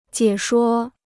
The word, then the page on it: 解说: jiě shuō